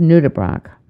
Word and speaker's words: nudibranch: It turns out I was pronouncing the word incorrectly. One says nudibranch.